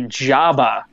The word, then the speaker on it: Jabba: My trail name is actually Jabba, as in the hut, as in two B's and the second one is silent.